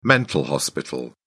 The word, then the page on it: mental hospital: British English